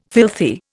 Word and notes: filthy: Indian

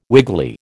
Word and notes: wiggly: American English

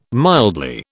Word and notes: mildly: British English

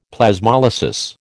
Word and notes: Plasmolysis: American English

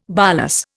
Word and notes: bolus: Indian

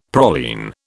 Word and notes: praline: British English

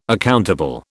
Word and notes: accountable: British English

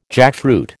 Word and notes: jackfruit: American English

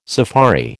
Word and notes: safari: American English

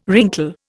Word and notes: wrinkle: Indian

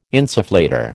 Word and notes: insufflator: American English

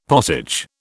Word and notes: potage: British English

